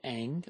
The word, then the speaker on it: Ingres: It was done by a French painter by the name of Ing, although his name is spelled I-N-G-R-E-S, like the database.